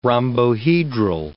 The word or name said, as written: rhombohedral